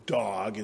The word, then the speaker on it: Doeg: And let me just pause because the narration tells us three times that this fellow, you can probably say his name simply as Dog as opposed to Doeg, probably has two syllables in the original.